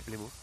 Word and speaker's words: Plymouth: Es que creo que se pronuncia Plymouth. A las 7 menos cuarto. – Sí, Plymouth. – Antes decía Plymouth. – Sí, pero creo que es Plymouth.